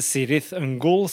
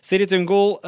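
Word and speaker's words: Cirith Ungol: Men uh, han hadde fått på seg nye Kirit Ungol, eller Sirith Ungol, som de uttaler det selv. Sirith Ungol uh, eh, starta allerede i 71 i California.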